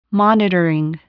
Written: monitoring

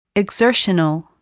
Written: exertional

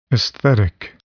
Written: aesthetic

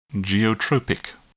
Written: geotropic

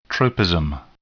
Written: tropism

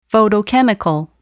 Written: photochemical

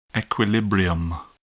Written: equilibrium

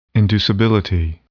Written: inducibility

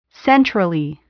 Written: centrally

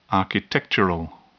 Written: architectural

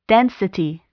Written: density

